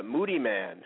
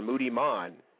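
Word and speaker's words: Moodymann: This is Moody Man, or Moody Mon, either way you want to pronounce that.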